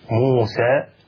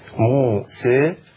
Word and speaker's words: موسى: فمثلا عندنا كلمة موسى في بين القراءات خلاف في قراءة الألف في كيفية قراءة الألف، فهناك الفتح موسى اسم الفتح، هناك التقليل أو الإمالة الصغرى موسى، وهناك الإمالة الكبرى موسى.